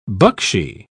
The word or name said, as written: buckshee